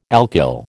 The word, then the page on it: alkyl: American English